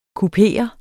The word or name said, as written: kuperet